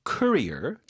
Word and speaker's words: courier: Summon a courier. 자, 뭐 우리가 경력, 커리어 얘기할 땐 career 뒤쪽에 강세인데 이건 앞에 강세예요.